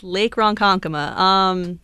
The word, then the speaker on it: Ronkonkoma: Lake Ronkonkoma? – Yes, Lake Ronkonkoma. You did pronounce it correctly.